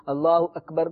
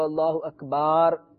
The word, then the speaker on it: اللہ اکبر: لیکن اب یہ ہے کہ ہماری عوام کو چونکہ عربی نہیں آتی، اگر کوئی غلط تلفظ سے اذان کہتے ہوئے اللہ اکبر کو اللہ اکبار کہتا ہے تو اس میں ہم کفر کا حکم نہیں لگائیں گے.